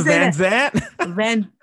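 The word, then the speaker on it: Vanzant: I thought it was Vanguard, no? – Iyana Van Zant. – Okay, I am Iyana, right? – Van Zant. – Iyana Van Zant. I've been saying her name wrong forever.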